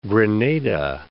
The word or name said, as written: Grenada